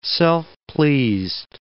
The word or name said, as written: self-pleased